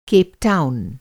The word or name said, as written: केपटाउन